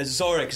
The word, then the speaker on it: Zarek: Yeah, Zarek. Zarek.